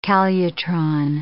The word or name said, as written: calutron